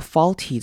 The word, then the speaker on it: faulty: 好，注意到 faulty 这个字，这边的 au 会念成短母音 o 的声音，r 的声音，faulty faulty，所以不用念成 a 或 u 本身的声音，faulty caused by faulty mood regulations regulations in the brain。